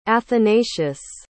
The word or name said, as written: athanasius